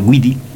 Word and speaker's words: Guidi: Giovanni Guidi, como él lo nombra, Guidi, no Guidi, nosotros lo diríamos Guidi. Ahí me enteré yo de cómo se pronuncia esto en italiano, ¿no? Guidi.